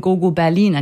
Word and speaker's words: Go Go Berlin: So I remember when we first met, you told me the story of how you originally chose the name Go Go Berlin and you absolutely wanted it to be pronounced German, which we pulled through straight.